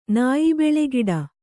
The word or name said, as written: ನಾಯಿಬೆಳೆಗಿಡ